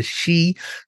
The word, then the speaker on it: Sidhe: or what's known as the Sidhe, that's spelled S-I-D-H-E.